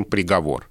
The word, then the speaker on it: приговор: Вот по этой же модели и происходит распространение ударения «договор», а когда-то было еще и «приговор». Такое ударение в 70-е, 60-е, 70-е годы прошлого века встречалась, даже попала в словари, но в литературном языке не удержалась, и мы сейчас по-прежнему говорим «приговор».